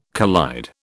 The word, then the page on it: collide: British English